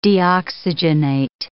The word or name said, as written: deoxygenate